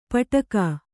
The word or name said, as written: ಪಟ್ಟುಕ